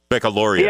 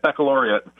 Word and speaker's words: baccalaureate: Did I say that right? Baccalaureate. – Yeah, international. Yep, baccalaureate. It's a fancy word.